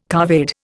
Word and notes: couvade: Indian

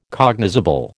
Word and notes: cognizable: American English

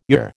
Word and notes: your: American English

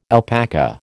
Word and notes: alpaca: American English

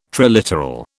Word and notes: triliteral: British English

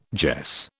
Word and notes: jess: British English

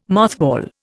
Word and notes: mothball: Indian

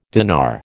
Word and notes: dinar: American English

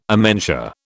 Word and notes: amentia: British English

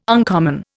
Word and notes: uncommon: Indian

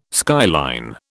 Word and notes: skyline: British English

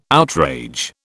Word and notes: outrage: British English

